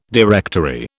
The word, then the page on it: directory: British English